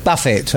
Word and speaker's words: Buffett: Buffett.